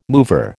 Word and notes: mover: American English

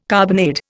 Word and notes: carbonade: Indian

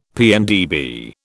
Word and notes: PNdB: British English

